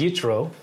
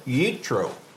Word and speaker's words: Yitro: And I can't pronounce it right, but this week is Yitro. – Yitro. All right. So, and this deals with the story of Moses and Jethro. Mm-hmm. So tell us what that Yitro meant.